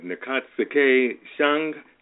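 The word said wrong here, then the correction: Ntozake Shange: Entozake Shange. – Entozake Shange.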